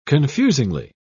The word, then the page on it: confusingly: British English